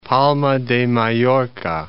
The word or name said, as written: Palma de Mallorca